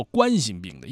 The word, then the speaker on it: 冠心病: 它为什么读作冠心病呢？因为是冠状动脉疾病，对吧？它这块这是哪块出毛病呢？是冠状动脉出问题了，这个产生疾病了，所以叫做冠心病啊。